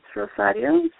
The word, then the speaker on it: Rosario: Yeah, it's, you want my, I say Rosary because that's what it translates to in English, but it's Rosario. So it's R-O-S-A-R-I-O. – R-O-E-S-E-R. – No. – Okay. And it's R-O-S-A-R-I-O.